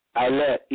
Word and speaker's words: Eilat: They spell it E-I-L-A-T, but they pronounce it Eilat.